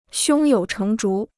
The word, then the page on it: 胸有成竹: xiōng yǒu chéng zhú